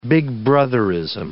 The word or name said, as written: Big Brotherism